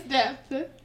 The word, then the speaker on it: depth: Yes, depth. – Happy. I know she's saying death. She said in death. In death. – Yes, D-E-P-T-H. – You're supposed to pronounce the P, but you know, I'm not here to teach anybody.